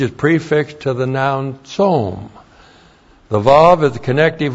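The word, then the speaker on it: Tsom (צוֹם): And then we have the noun tsom. T-S-long-O-M. Tsom.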